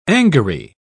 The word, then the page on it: angary: British English